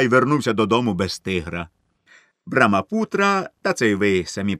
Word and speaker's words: Брамапутра: Слово «Брамапутра», щоправда, щодо вимови трудне, але їхавши на пароплаві, ви частіше його самі собі проказуйте, щоб навчитися його вимовляти.